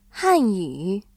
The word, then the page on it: 汉语: hànyǔ